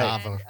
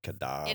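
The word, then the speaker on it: Kadaver: It's Cadaver, spelled with a K, because that's the Norwegian way of spelling it. – Right. – Cadaver. And, Cadaver.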